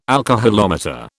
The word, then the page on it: alcoholometer: British English